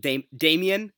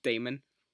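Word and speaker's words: Damian: I like how you said Damien. Don't forget the I. It's a little Damon. Matt Damon.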